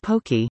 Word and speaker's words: poke: What is poke? Poke, which rhymes with okay, is a hearty meal, featuring chopped up and marinated protein, usually seafood, with a variety of vegetables, rice, and sauces.